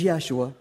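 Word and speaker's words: Yeshua (ישוע): This is Yeshua, right? Which is how you would say his name in Hebrew.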